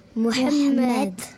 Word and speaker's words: Mohamed: Mohamed. – Voilà, d'accord, on sent un accent... sur la deuxième syllabe quand même, plus prononcée.